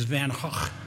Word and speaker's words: Van Gogh: And the correct pronunciation is Van Gogh. It's not quite that harsh a Gogh. It's a Van Gogh.